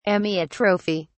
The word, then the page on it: amyotrophy: British English